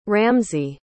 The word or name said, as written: ramzi